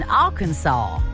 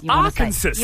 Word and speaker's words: Arkansas: He was spotted in the crowd at Ella Langley's concert in Arkansas. Do you know that's how you pronounce it? Not Arkansas. – Arkansas. – Yeah, I didn't Arkansas. Know that. Because it's like saw.